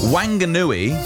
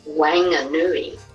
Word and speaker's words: Whanganui: Just wanted to say that the pronunciation of Whanganui is Whanganui. – Whanganui. So hang on. So somebody's saying that it needs to be a F, and then somebody's saying there's no G, so it's Whanganui.